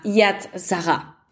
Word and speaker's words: Yad Sarah: Pero entonces imagínense que en este viaje conozco un proyecto y una organización que se llama Yad Zaha. Esto se escribe Y-A-D, Yad, y eh, como Sara, S-A-R-A-H.